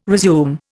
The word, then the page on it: resume: Indian